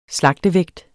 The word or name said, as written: slagtevægt